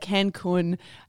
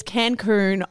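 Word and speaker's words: Cancún: Apparently I said Cancun. It's Cancun.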